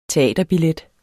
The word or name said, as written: teaterbillet